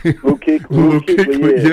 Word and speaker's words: Luke Kuechly: But you got Shaq Thompson, who's actually leading the team in tackles ahead of Luke Lee. Luke Lee, or however you say his name. – Luke Kuechly, yeah.